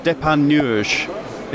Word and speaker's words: Depardieu: The Legacy of Depardieu. Is that right? Did I pronounce it right? – Perfect, perfect pronunciation.